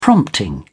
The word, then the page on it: prompting: British English